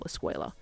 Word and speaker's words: eskwela: The Filipino word escuela is spelt how, to me, someone who doesn't speak Spanish would most likely spell escuela if you asked them to sound it out. So in Spanish, it's spelt E-S-C-U-E-L-A, and in Filipino, it is spelt E-S-K-W-E-L-A, escuela. So again, escuela, and it means school. Another word is the word for bathroom, baño. In Spanish, it is B-A-N-O, and the N has a tilde over it, and in Filipino, it is B-A-N-Y-O, baño.